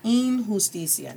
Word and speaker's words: injusticia: MLK says, la injusticia, and I refer to this quote todo el tiempo, la injusticia en cualquier parte, la injusticia, not injusticia, injustice, A-E-E, I is pronounced as E, la injusticia, and J has an H sound, la injusticia en cualquier parte es una amenaza a la justicia en todas partes.